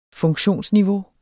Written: funktionsniveau

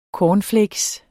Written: CORNFLAKES

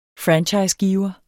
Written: franchisegiver

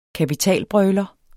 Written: kapitalbrøler

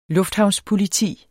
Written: lufthavnspoliti

